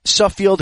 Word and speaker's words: Suffield: So, now back in high school, when you went to Suffield Academy, am I saying that correctly? I said that correctly, right? – That's right. Yep.